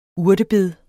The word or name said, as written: urtebed